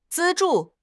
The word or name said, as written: 资助